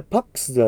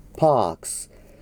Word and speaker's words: pax: そして et in terra pax、これパックスではないから気をつけてください。パークス。